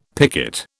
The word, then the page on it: picket: British English